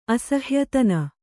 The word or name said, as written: ಅಸಹಾಯತನ